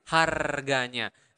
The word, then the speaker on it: harganya: Remember in Bahasa, you really pronounce the R, so you need to roll your tongue and make that sound. – Harganya, harganya. – Okay, not like price. In because in English, the R doesn't really hurt.